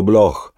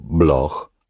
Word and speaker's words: Bloch: Lei, che era stata Mademoiselle Bloch sin dalla nascita, rispose pronunciando Bloch alla tedesca, come se la CH non fosse stata una C o una K, ma la CH tedesca, come avrebbe fatto il duca di Guermont.